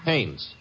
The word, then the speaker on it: Haines: did you say his name is? – Haines. H A I N E S. Ah, oh, Haines.